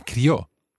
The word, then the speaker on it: crió: We do stress the syllable, crió. Fernanda, can you explain what's going on here? – Perfecto. Es porque es una monosílaba, una palabra monosílaba. Mm-hmm. Entonces no lleva acento. – Okay. – Es solo una sílaba. Pero, por ejemplo, crío, mm-hmm. si fuera un niño, sí. – lleva acento en la I. Y son dos sílabas.